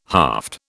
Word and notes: haft: British English

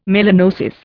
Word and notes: melanosis: Indian

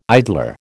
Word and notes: idler: American English

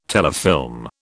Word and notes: telefilm: British English

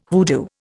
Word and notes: hoodoo: Indian

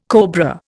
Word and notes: cobra: Indian

